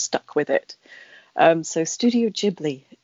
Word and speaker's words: Ghibli: What's the correct pronunciation? – So this is a funny story in some ways. So it's a mistake. Apparently Hayao Miyazaki came up with the way of pronouncing this, and it was in Japanese, Studio Ghibli. So it's with a J-I in Japanese at the beginning of the second word.